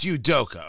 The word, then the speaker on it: Udoka: Before I let you go, this story around the Boston Celtics coach, whose name, give me the proper pronunciation. – Ime Udoka. – It's Udoka. Okay, I wanted to make sure I was right.